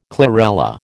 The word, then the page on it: chlorella: American English